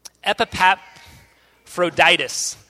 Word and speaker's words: Epaphroditus: His name is Epaphroditus.